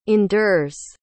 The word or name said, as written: endures